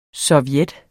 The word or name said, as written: SOVJETTERNES